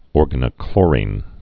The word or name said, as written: organochlorine